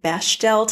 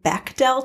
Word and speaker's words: Bechdel: I pronounced it Bechdel test for most of my life. And last week I found out it's pronounced Bechdel test.